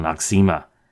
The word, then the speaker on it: Noxzema: No-X-Zema, no eczema, or as we normally pronounce it, Noxzema.